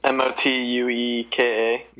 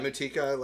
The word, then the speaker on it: Motueka: Yeah, so it says, we've chosen a communion of Citra and Motueka. Motueka? I don't know how to say it. Motueka. – Yeah, Motueka, like Yukika, yeah.